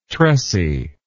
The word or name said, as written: tressy